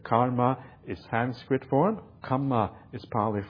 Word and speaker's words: kamma: Kamma has only one M, and it has a long A, an A with this little bar across it at the top.